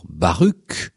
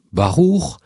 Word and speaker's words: Baruch: Baruch, que l'on prononce souvent à tort, d'ailleurs, Baruch, car la prononciation hébraïque est bien celle de Baruch, signifie le béni, qui aboutira dans nos civilisations au prénom Benoît.